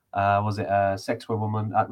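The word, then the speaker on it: Sacsayhuamán: Sexy woman? – Yeah, that was what the guide said to pronounce it.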